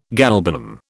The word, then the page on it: galbanum: British English